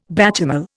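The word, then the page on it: béchamel: Indian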